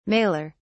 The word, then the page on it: maler: British English